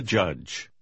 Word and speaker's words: judge: You be the judge. Judge. – Обратите внимание, что буква И немая. Она не меняет краткий гласный звук U на долгий. Так часто бывает, когда буква И стоит после двух согласных.